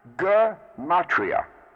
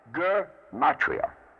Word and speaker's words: Gematria: One of the common mispronunciations, and it's found very frequently, is gematria. Now, this is wrong. It's a hard G. Gematria. Gematria.